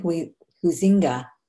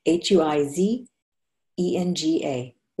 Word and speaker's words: Huizenga: Billionaire serial entrepreneur Wayne Huizenga, Huizenga, I think it's pronounced, it's spelled H U I Z E N G A, Huizenga, said, That's what makes brands great consistency and uniformity.